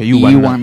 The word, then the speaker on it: Ewan: Ewan